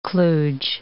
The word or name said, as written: kluge